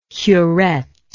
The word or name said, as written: curette